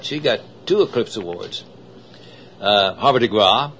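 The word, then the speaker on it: Havre de Grace: As a matter of fact, they pronounced it G-R-A-W, Harbor de Gras.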